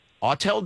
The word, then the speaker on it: Autel: Are you hip to, I hope I'm pronouncing it right, Autel devices? – I know they make, like, small drones. – Autel does?